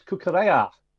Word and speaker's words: Cucurella: No, all I was going to say is what I liked about Adam, he has done the correct pronunciation. It's Kukurea. – Well, I am. – Cucurella. – I'm half Spanish, aren't I? So that's the... Yeah, so exactly, Adam. You know, so I would go with your pronunciation rather than people who think it rhymes with Estrella.